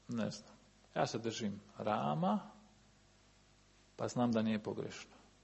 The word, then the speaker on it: Rama: A u sanskritu je A. A. Rama. Rama. Rama. A. A. Malo je kraći. Malo je kraći A nego naše.